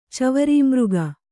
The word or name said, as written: ಚವರೀಮೃಗ